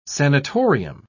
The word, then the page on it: sanitorium: British English